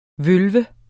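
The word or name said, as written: VØLVE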